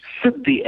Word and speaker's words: Siddhi: Siddhi, S-I-D-D-H-I, Siddhi.